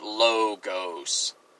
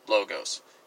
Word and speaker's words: logos: It is a long O sound. The letter Omicron in Greek makes a O sound, so it's logos, not any other pronunciation, logos.